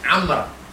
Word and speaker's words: Amr: Kalau ada wawnya, bacanya Amr. Kalau tak ada wawnya, bacanya Umar.